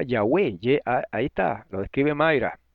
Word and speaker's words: Yahweh: Con acento en la E. Es una palabra de dos sílabas y es una palabra aguda. Entonces es Yahweh, no es Yahweh.